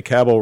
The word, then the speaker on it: Cabell: Now, do I say Cabell right, Bill? Because I know you're a Cabell scholar. So. – Yes, it rhymes with Rabble.